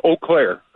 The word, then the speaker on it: Eau Claire: But the pronunciation, it's Eau Claire.